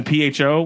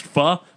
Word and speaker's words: pho: So it's lowercase I and then PHO, which is like Vietnamese noodles pronounced pho. And I had to confirm that with the people. It's pho. – Pho as in what the pho.